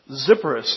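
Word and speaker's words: Sepphoris: Am I pronouncing that right? Is that Zephoris or Zephoris? Zephoris. That's easy to pronounce. Zephoris.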